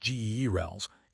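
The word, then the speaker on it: Gehrels: Meanwhile in space, the Neil Gerald Swift Observatory, pronounced GERLS, is facing a challenge.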